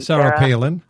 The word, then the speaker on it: Sarah Palin: And another thing is, I believe Sarah Palin. How do you say her name? – Sarah Palin.